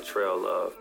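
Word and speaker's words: Trellove: Like I said, my name, Trail Love. That's spelled T-R-E-L-L-O-V-E. I know a lot of people be wondering, but yeah, it's my real name.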